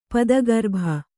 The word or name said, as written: ಪದಗರ್ಭ